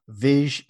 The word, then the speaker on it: Vizh: Instead of calling him Viz, like V I Z, it's spelled V I Z H. Viz.